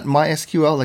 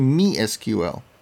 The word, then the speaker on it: MySQL: And by the way, I should note, I pronounced it incorrectly. It's not MySQL like most of it. It's actually MeSQL.